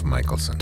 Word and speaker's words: Mickelson: Did I get that right? – Yes. – Is that that's how you say it? – I say Dove Mickelson.